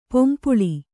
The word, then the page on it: ಪೊಂಪುಳಿ: pompuḷi